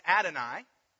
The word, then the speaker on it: Adonai: When they came to this, they would pronounce it Adonai.